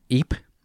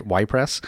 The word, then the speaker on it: Ypres: And the town of Ypres, as Lee has informed me, is pronounced, not Ypres, has stolen it from us.